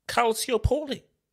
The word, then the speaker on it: Calciopoli: Calciopoli. That's how you say it.